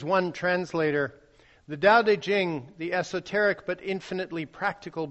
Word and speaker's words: Tao Te Ching: The Tao Te Ching, pronounced with a D but spelt with T's, Tao Te Ching, is the founding text of Taoism.